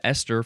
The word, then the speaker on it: Esther: Capital E-S-T-H. This is an abbreviation for ester, which is our next word. Huh? Ester. It sounds the same as Esther from the previous episode, but it's spelled with an H because it's a name.